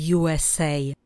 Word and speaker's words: USA: Přečtěte na hlas. USA. USA. České U, anglicky U. S, stejně česky jako anglicky. Pozor na samohlásku A, kterou v angličtině vyslovujeme A. USA. A nepleťte si S, které i v angličtině vyslovujeme jako S, a C, které v angličtině zní C.